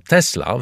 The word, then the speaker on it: Tesla: Nazwisko Tesla, wymawiane z równym akcentem na obie sylaby, w czysto dosłownym znaczeniu jest nazwą zawodu, jak Smith – kowal, Freiter – woźnica lub Carpenter – stolarz.